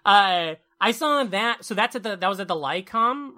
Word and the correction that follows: Lyceum: right? – The Lyceum. – Is that what it's pronounced? Lyceum.